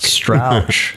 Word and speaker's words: Strauch: Is it Strauch or Strauch? – Strauch. That's actually Strauch. Yes. Is the correct pronunciation.